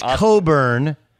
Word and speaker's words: Cockburn: Coburn. But it's a lot like Kofi Coburn. It's spelled C-O-C-K-B-U-R-N.